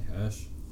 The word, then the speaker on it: Hirsch: Is that how you say his name? – I say Hirsch. – Hirsch. – It looks more complicated than it is, I think.